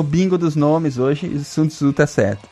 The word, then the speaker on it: Sun Tzu: É assim que fala mesmo? Sun Tzu? – É a primeira pronúncia certa da noite? Não, tá certo, Sun Tzu.